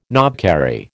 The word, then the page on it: knobkerrie: American English